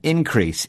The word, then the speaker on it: increase: Lucy says there will be an increase in people wanting concert tickets. – Yes, and increase here is a noun, so the stress is on the first syllable.